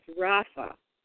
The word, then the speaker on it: Rapha: Rapha. That's the way you pronounce it.